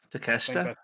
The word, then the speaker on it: Takeshita: But Takeshita? Yeah. Takeshita.